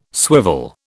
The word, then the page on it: swivel: British English